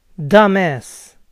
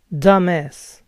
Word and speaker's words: dumbass: Dumbass. Dumbass. Recuerden no pronunciar la B grande.